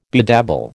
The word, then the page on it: bedabble: American English